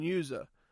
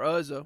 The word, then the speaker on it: Uzzah: I tell them the story of the Ark of the Covenant, and and and and Uzzah or Uzzah, whichever way you want to pronounce that.